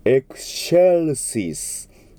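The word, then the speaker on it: excelsis: この X の後ろについている CE、これはそれだけだったらチェと読まれるんですが、 X とつながりますのでシェという発音になります。エクシェルシース、エクシェルシース。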